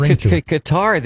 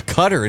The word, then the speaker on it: Qatar: Well, because Qatar, there's two accepted pronunciations of it. So you, every time you do one of them, you think you should be doing the other one. – Oh, fair enough. – Right? Because cutter is the other way to say it, which doesn't look anything like Q-A-T-A-R.